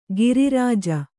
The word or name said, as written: ಗಿರಿರಾಜ